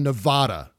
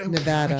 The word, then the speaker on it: Nevada: I would say keep a closer eye on Nevada than ever. Yeah. Or Nevada. What? – Nevada.